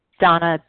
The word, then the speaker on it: Dana: And my name is spelled D-A-N-A. So sometimes people have a hard time finding me. But D-A-N-A. Donna B. from Colorado.